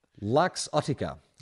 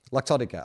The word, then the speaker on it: Luxottica: Luxottica. Luxottica. Oh, like luxury. Luxottica? Would it be Luxottica or Luxottica? Luxury. L-U-X. Luxottica. Let's call it Luxottica.